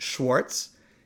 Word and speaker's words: Schwarz: The word Schwarz, in English, we would pronounce it Schwartz, is the Yiddish word black.